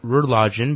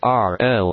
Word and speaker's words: rlogin: JAWS pronounces it Relogin, but it's actually Relogin. R-L-O-G-I-N. – R-Login, which is remote login.